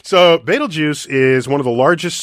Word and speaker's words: Betelgeuse: Betelgeuse. And that's how you pronounce it, Betelgeuse. – Betelgeuse.